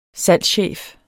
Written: salgschef

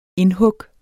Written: indhug

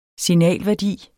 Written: SIGNAL

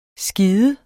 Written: skide

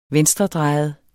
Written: venstredrejet

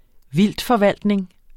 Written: vildtforvaltning

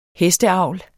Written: hesteavl

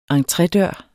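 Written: ENTREDØRENE

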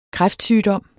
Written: kræftsygdom

